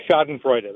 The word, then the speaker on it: Schadenfreude: Well, Schadenfreude, that's how you say it. – Yeah, okay. Say it one more time. – Schadenfreude. – Schadenfreude, okay. That's the real way.